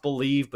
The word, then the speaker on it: Bleav: Believe, pronounced Believe, but spelled B-L-E-A-V.